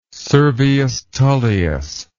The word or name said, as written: Servius Tullius